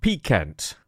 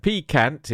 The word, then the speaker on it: Pikant: This is a little channel called Captain Picant, and that is spelled Captain, as you would expect, and Picant is spelled P-I-K-A-N-T.